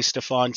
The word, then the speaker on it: Stefan: And you'll hear people call me Stefan too. And that's totally acceptable because the Cajun side of my family, the Creole side, uses that pronunciation. So I answer to both.